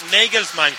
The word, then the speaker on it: Nagelsmann: Claro, es Nagelsmann.